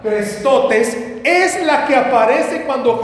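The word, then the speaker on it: Crestotes (χρηστότης): Es, mire, se le escribo cómo está y ahí abajo le pongo cómo se pronuncia. Crestotes. Diga conmigo, crestotes. No, pero dígalo, crestotes.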